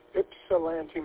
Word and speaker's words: Ypsilanti: Next up, we have WB8ODF, I'll try my best to pronounce this, Ypsilanti, Michigan. – Hi, everybody in the background there. I did hear the pronunciation, but yes, that is correct. Ypsilanti, Michigan, and I think a lot of people are going to like my idea here.